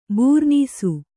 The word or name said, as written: ಬೂರ್ನೀಸು